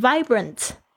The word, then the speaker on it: vibrant: Young, alright, 好，接下来这个字我也非常喜欢，就是充满活力的这个字，但是它不太好发音的是它的 V 的声音。我有很多的学生呢，最痛苦的就是这个 V 的声音发不好。这个 V 其实是上排的牙齿轻轻的这个咬到这个下排嘴唇的一个 V 的发音。 Vibrant, I feel young and vibrant, 就是我感到年轻而充满活力。